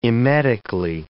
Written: emetically

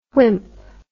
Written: whimp

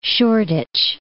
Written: Shoreditch

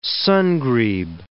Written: sun-grebe

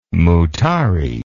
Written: mutare

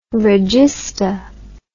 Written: regista

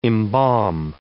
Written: embalm

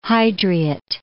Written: Hydriot

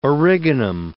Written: origanum

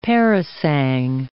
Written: parasang

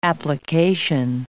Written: application

